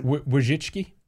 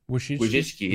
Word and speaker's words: Wojcicki: He also talked about how he got a letter from Susan Wojcicki or Wojcicki, whatever her name is. Do you know how to pronounce her last name? – Wojcicki. – Wojcicki, yeah, Susan Wojcicki, the CEO of YouTube.